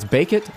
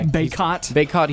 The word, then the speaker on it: Bacot: I think it's Bacot is how you say it. I think he's going to have a good game today. – Bacot, he's been playing solid.